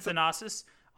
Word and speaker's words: Thanasis: So Giannis and Thonis, I think is how you pronounce his name. – I thought it was Thanasis. Maybe, I don't know. – Giannis and Thanasis.